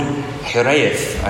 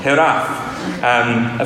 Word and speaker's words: Hiraeth: It's called Hiraeth. I don't know if I pronounced that well at all. Hiraeth. Is that right? Hiraeth. Hiraeth. Okay, completely wrong. Hiraeth.